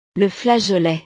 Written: le flageolet

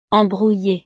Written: embrouillee

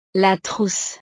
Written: la trousse

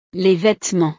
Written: les vêtements

